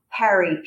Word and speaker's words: Parry: And I'm going to say to you, spell out your name, because Parry could be Parry or Parry. It could be. So spell that for people so they really can find you. – It's Carolyn, Carolyn, Parry, P as in Peter, Arry.